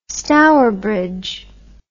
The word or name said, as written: Stourbridge